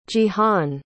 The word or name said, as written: jihan